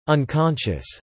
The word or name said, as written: unconscious